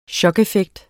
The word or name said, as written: chokeffekt